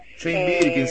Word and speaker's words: Jane Birkin: Escucho, no lo sé pronunciar, pero escucho Jean Birkin, Jane Birkin, sí, buenísimo.